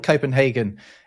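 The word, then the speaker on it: København: I mean, it's not called Copenhagen in Danish. It's called Copenhagen. Right? It's spelled a bit differently. But it means merchant's harbor, and the Hagen, or Havn, which is spelled H-A-V-N in Danish,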